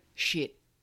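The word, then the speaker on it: shit (쉿): Um the next one, which I love, uh, is the Korean word shit. And this means like shush, but it has a T sound at the end. Shit. Like shit.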